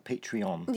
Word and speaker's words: Patreon: Patreon.